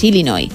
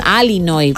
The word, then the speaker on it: Illinois: Pero es Illinois, o sea, si tú lo pronuncias bien y tienes buena dicción, es Illinois. – Illinois. – Luego, si lo pronuncias rápido, según el acento, como decía Beto también, pues te sale una medio E, pero nunca un Illinois, Illinois.